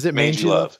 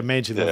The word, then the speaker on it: Mangy Love: How do you pronounce that? Mangy or Mangy Love? What is that, Rob? – Mangy Love. – Okay, Mangy Love.